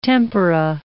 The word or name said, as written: tempera